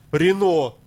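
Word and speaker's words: Renault: французский так вообще, там еще все ре, Рено, пишется Ренаулт, он читается как Рено, да, да.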